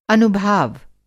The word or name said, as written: अनुभाव